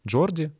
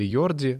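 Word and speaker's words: Jordi: Джорди, или Йорди, э, по-разному произносится это имя в зависимости от языка, но так он подчеркнул свою любовь к этому городу в том числе.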